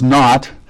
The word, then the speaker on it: know: The alpha privative means not, and gnosis, agnostic, gnosis means knowing. That's how we get. That's why you have a silent K in front of no.